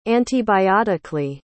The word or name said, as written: antibiotically